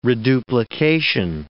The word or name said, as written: reduplication